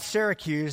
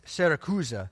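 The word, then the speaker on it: Siracusa: So verse 12, it says, after we put out at Syracuse, that's not how you pronounce Syracuse, it's Syracusa, and that is in Sicily, the wonderful island of Sicily that I long to go.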